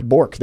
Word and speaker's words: Bourque: Maverick Bourque.